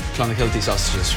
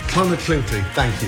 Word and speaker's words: Clonakilty: Irish tea bags are Clonakilty sausages from Cork. – Clonakilty. – Clonakilty. – Clonakilty, thank you.